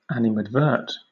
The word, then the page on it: animadvert: British English